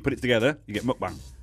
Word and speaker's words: mukbang: Do you know how to pronounce it? It's mukbang.